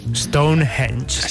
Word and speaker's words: Stonehenge: Stonehenge.